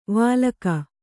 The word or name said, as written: ವಲ್ಕೆ